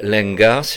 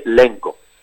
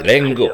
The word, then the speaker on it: Lenga: Mais un occitaniste vous dirait Lengo. Le A est occlusif à la fin. – Alors je dirais Lengo, alors. Voilà, Lengo, à l'affiche de l'apostrophe théâtre 95 à la salle Visconti à Cergy, les mardis 13 et mercredi 14 mars à 20h30.